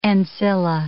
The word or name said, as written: ancilla